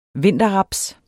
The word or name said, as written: vinterraps